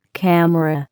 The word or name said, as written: camera